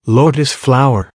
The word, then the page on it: lotus flower: American English